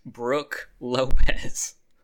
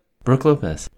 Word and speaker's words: Brook Lopez: Brook Lopez. – I said it like that on purpose. – Did you though? – Brook Lopez.